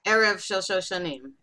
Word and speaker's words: Erev Shel Shoshanim: Did I pronounce it anywhere near correct? – Erev Shel Shoshanim.